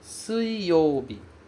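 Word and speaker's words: suiyobi (水曜日): Então, você tem o dia do fogo, e logo em seguida, para apagar o fogo, você tem o dia da água, que é pronunciado suiyobi. Suiyobi, ok? Sui.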